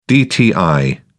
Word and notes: dti: British English

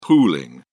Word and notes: pooling: British English

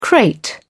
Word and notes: crate: British English

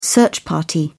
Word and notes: search party: British English

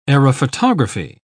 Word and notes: aerophotography: British English

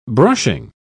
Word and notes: brushing: British English

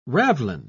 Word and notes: ravelin: British English

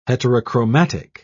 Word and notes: heterochromatic: British English

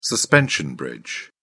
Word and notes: suspension bridge: British English

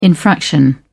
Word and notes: infraction: British English

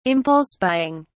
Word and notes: impulse buying: British English

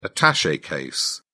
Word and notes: attache case: British English

